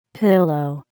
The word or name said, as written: pillow